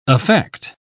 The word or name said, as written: affect